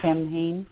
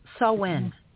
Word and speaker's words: Samhain: It was joyful, and I love hearing other people's take on Tim Hain. Am I saying it right? – Yeah. Samhain.